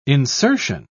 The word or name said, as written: insertion